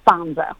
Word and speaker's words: Fanzo: Either. Italian Americans would call it Fonzo.